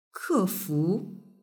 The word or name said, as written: kefu